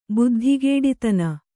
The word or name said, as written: ಬುದ್ಧಿಗೇಡಿತನ